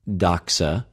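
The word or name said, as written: δοξα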